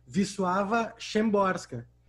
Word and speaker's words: Wisława Szymborska: e aí coloca entre parênteses, pronuncia-se. E aí ela coloca uma espécie de transcrição facilitada para um pangaré como eu, né? E aí diz ali, pronuncia-se Wisława Szymborska.